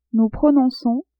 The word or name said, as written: nous prononçons